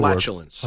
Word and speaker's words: flatulence: I said that word wrong. How do you say the flatulence. – Word? Flatulence. Oh, I said it right. – Okay. No, you didn't. You said fatulence.